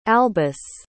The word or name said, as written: albus